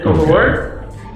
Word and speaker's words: Velours: The concept is actually, I'm using the French spelling and pronunciation, so that S is actually silent. So it's just Velours, like the way you say it in English.